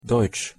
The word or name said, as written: deutsch